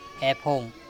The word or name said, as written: EpHong